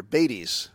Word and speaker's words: Beytes: Bates. – Bates. It's spelled B-E-Y-T-E-S.